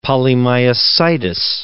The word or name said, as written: polymyositis